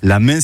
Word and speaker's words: ma: La main se prononce en occitan la ma, mais aussi la man, notamment en volvestre, en comminges, en lomagne, dans le Gers.